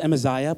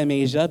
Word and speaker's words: Amaziah: I'm pretty sure it's pronounced Amaziah, but I want to say Amasia because I'm ghetto like that.